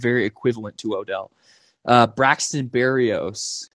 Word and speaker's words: Berrios: It's Berrios. It honestly kind of sounds just like it's spelled just like it sounds. It's Berrios.